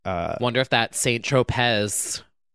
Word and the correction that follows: Saint-Tropez: Is that the right word? – Saint-Tropez. – Oh. – You put the emphasis on the wrong syllable.